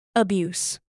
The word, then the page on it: abuse: verb